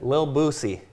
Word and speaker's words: Lil Boosie: Little Boozies. – You're, you're close. – How you say it? Lil Boosie. Lil Boosie. There's no T in Lil.